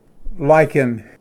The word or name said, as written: ᎡᎵᎩ